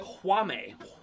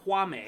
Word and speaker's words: Jaume: Kwame. Kwame. – How do you spell it? – J-A-U-M-E.